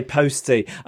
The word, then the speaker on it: postie: Am I saying that right? Posty or postie? – You'd say postie.